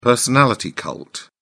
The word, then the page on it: personality cult: British English